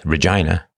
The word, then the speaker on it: Regina: He holed up in his room, quit the drugs, quit Regina, rhymes with done, and tried to regain his sanity.